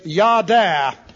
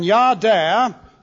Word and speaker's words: yada: The Hebrew word which is used for knoweth is yada. And yada, that would be J-A-H-D-A, well, so D-A-Y, yada, it's pronounced.